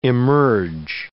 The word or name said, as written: emerge